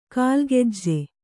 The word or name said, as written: ಕಾಲಗೆಜ್ಜೆ